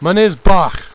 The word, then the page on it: Mynyddbach: Welsh